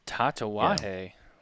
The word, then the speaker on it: Tatuaje: Smoking a good old classic Tatuaje. Somebody's calling me. Sorry about that. Tatuaje, the Negociant Monopole. I probably butchered the pronunciation. – Tatuaje.